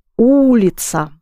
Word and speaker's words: улица: The word for street is улица. Улица. This word rhymes very well with нравится. Yes? Нравится. Улица.